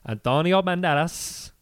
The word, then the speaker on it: Antonio Banderas: I want to say 1998, an action film starring Antonio Banderas. Legally, you have to say his name like that.